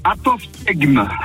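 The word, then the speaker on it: apophtegme: Apophthegme.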